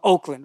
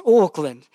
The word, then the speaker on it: Auckland: Yes, sir, we're going to Auckland, He says, sweet ass, we're going to Oakland, right? Yes, it's Auckland. And of course, the story. He found out that he was flying to Auckland. That's the proper way to say it.